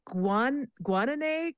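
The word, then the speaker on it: Guanay: Guanine cormorant, is that how I pronounce the bird? Yeah.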